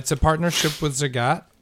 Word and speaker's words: Zagat: That's right. Zagat rhymes with cat. Zagat rhymes with cats.